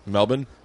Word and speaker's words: Melbourne: Melbourne? That's how it's pronounced, and people give me shit for it, and it's like, say it like it's, and I'm like, would you say Worcester?